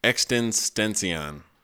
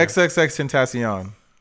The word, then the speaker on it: XXXTentacion: Extensión. – Why did you just purposely butcher his name? – Tentacion. – Yeah, XXXTentacion. Triple X Temptation.